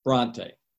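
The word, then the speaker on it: Brontë: And it turned out that they think it belonged to the Brunette family, Charlotte Brunette, if I'm pronouncing the name right, the English author who wrote the book known as Jane Eyre. Bronte, thank you.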